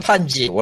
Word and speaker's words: 판지: 어, 그 이름도 유명한 펜지, 판지요, 판지. 원래 판지인데, 일본어로 쓰면은, 그냥 한글로는 펜지라고 써놨더라고. 왜냐, 발음이 비슷하거든요, 일본어 팬티랑 어, 사랑받지 못한 팬티들의 동지를 모아서 인간 세계를 친 다음에 모두가 팬티만 사랑하던 만든다라는 아주 못된 응?